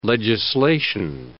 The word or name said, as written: legislation